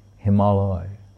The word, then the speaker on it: Himalaya: When I say Himalaya, no, Himalaya. Himalaya. Because it's Hema and Aloy, Himalaya. Like Himavati, Himalaya.